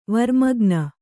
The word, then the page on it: ವರ್ಮಗ್ನ: varmagna